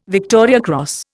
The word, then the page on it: Victoria Cross: Indian